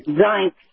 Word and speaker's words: Zients: Zients.